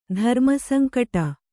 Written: ಧರ್ಮಸಂಕಟ

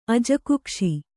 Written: ಅಜಕುಕ್ಷಿ

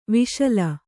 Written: ವಶೀಲಿ

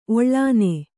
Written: ಒಳ್ಳೆಣ್ಣೆ

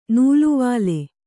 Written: ನುಲುವಾಲೆ

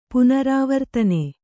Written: ಪುನರಾವರ್ತನೆ

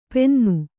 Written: ಪೆನ್ನು

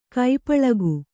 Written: ಕಾಪಾಳಿಕ

